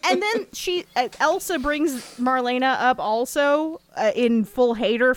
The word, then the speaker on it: Marlene: I guess that's pronounced Marlena, because everyone's saying it Marlena, but it looks like Marlene.